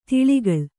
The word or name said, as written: ಧಾಳಿಗೊಳ್